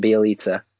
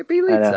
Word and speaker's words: Bjelica: Nemanja Bjelica? Is that Bjelica? – No way. No way. – That's how you say it, Bjelica. – Okay, Bjelica.